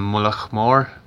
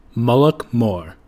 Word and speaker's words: Mullaghmore: But New Zealand listener Chris Rainbow Johnson couldn't stand me mispronouncing the name of that surf spot, so he took the time to record his Irish mates saying it. Here's another one. – How are you? I'm not from Mullagh Mór, but that's how it's said.